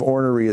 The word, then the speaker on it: ornery: My family's from Kentucky, so the proper pronunciation of ornery is ornery.